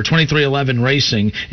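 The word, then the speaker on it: 23XI Racing: Jordan named his team 23 Racing, pronounced 2311 Racing,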